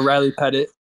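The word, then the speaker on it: Riley Pettitt: So uh, Riley Pettit.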